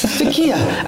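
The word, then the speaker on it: Türkiye: Takai. – Takia, actually.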